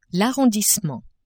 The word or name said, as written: arrondissement